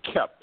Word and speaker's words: Koepf: I always tell people it's a tough one to pronounce, so you can just call me Scott. – But how do you pronounce your last name? – It's Kemp.